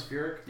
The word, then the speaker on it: Furyk: Did they say his name is Furyk? – Yeah, yeah.